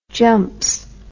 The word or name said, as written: jumps